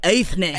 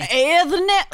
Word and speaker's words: Eithne: She was, so originally her name in Gaelic, it is spelled E-I-T-H-N-E, which looks like Aithne. – Aithne. – Aithne.